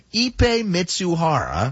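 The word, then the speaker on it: Ippei Mizuhara: There's a phonetic pronunciation, Ipe Mitsuhara.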